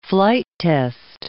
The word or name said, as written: flight-test